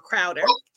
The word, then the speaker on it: Crowder: chowder crowder crowder look i pronounced it wrong crowder there is a there was a clip circling around again, men with podcasts.